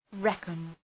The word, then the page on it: reckon: ‘rekən